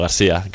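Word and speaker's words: Garcia: Bueno, García, catalán. – García, Joan, sí, García. – Sí, sí. – Ya está. – En catalán García, en castellano García.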